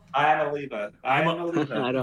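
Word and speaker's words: Iamaleava: Is it Nico Iamaliva? Is that... – I think it's Iamolova? – Iamolova? – Iamolova.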